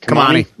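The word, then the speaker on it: Kimani: Well, we talked about them in DFS, but available in 94% of leagues is, how does he pronounce it? – Kamani. Kamani. Yep.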